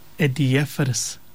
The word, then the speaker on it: adiaphorous: The word that we were looking for, Malanai, is this word, adiaphorous. Adiaphorous. Adiaphorous is spelled A-D-I-A-P-H-O-R-O-U-S. Adiaphorous.